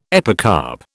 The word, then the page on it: epicarp: British English